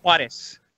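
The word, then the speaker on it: Juárez: Juarez.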